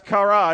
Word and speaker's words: kara: The word call is a Hebrew word. It's pronounced kara, and it literally means to get someone's attention for a response.